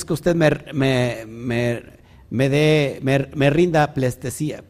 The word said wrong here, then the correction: pleitesía: plestesía. ¿Sí se dice así? pleitesía. ¿Y qué dije? Plestesía. ¿Y cómo es?